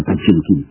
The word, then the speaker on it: Potemkin: Au mois de juillet, on aura la révolte du cuirassé, la ultime révolte, vous savez, du cuirassé, nous prononçons Potemkin, hein, cuirassé Potemkin, paraît-il, il faut prononcer ça, Potemkin.